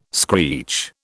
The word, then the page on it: screech: British English